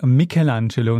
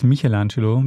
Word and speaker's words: Michelangelo: Es heißt Michelangelo, nicht Michelangelo, wie ich das gesagt habe. Das CH mhm. spricht man im Italienischen nicht.